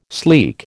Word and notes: sleek: American English

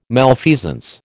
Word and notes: malfeasance: American English

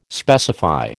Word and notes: specify: American English

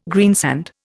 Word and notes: greensand: Indian

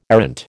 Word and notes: arrant: American English

